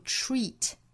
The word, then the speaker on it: treat: 我们这里面是包含一个长元音紧元音的 E 的发音，读成 treat。 treat 和 disagree 后面这个音节 E 的这个音是一样的，所以 treat 也好 disagree 也好，在发音的时候呢，上下齿几乎没有间距，左右嘴角尽量裂开，发一个 E 的发音 treat disagree treat disagree。